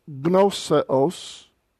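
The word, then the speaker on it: gnoseos (γνώσεως): Didaskalon, nepion, ekonta, tain, morphosin, tais, gnoseos, and again the gamma and nu are pronounced together with no helping vowel between it, gnoseos.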